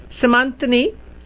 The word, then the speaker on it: Simantinee: Simantinee, did I say it right? – Yep.